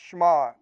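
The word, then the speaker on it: shema (שמע): Now, I'm not a Hebrew expert, but the Hebrew word for hear is a word shema, or pronounced shema, like one syllable.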